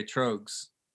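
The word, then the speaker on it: Tröegs: All right, it rhymes with rogues. – Okay, trogues.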